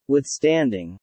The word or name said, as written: withstanding